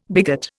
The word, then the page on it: bigot: Indian